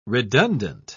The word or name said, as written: redundant